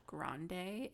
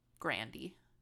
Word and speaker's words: Grande: Um, and Ariana Grande is actually like Grandy. Grandy? really? Yeah. Grandy.